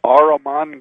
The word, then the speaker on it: Ahriman: And how do we pronounce the name of that gate? – The Ahriman Gate. – Ahriman. – Yes. – Okay, Ahriman Gate. Mm-hmm. Okay, got it. Ahriman. That's A-H-R-I-M-A-N, right? – That's right.